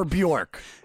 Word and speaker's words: Björk: Goldie called her Björk. – When you hear me say that, just know you can think it whatever you want, but that's how she actually pronounces it.